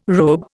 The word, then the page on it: robe: Indian